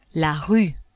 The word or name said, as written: rue